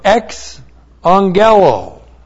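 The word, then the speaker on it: ex angelo (ἐξαγγέλλω): Now, that verb to proclaim, it's the aorist active subjunctive of ex angelo. E-K-S-A-N-G. When you have two gammas together, the first one is transliterated as N-G-E-L, long O.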